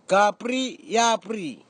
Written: kapri iapri

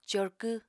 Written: corkw

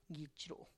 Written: gitx ru

